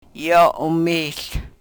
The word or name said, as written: yo u-mihl